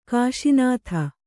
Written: ಕೋಶನಾಥ

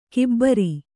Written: ಗೊಬ್ಬರ

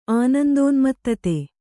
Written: ಆನಂದೋನ್ಮತ್ತತೆ

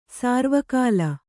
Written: ಸಾರ್ವಕಾಲ

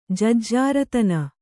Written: ಜಜ್ಜಾರತನ

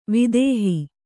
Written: ವಿದೇಹ

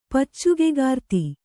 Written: ಪಚ್ಚುಗೆಗಾರ್ತಿ

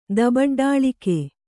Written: ದಬಡ್ಡಾಳಿಕೆ

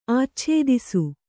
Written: ಆಚ್ಛಾದಿಸು

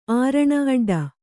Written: ಆರಣ ಅಡ್ಡ

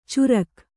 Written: ಚುರಕ್

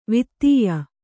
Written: ವಾತ್ಯೆ

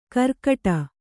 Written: ಗಿರ್ಗಟ

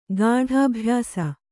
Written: ಗಾಢಾಭ್ಯಾಸ

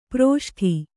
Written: ಪ್ರಷ್ಠ

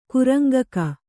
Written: ಕುರಂಗಕ

